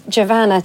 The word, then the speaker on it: Giovanna: I probably would have said I was speaking to Giovanna Fletcher, but she very helpfully put a little post up saying that it's Giovanna to rhyme with Savannah.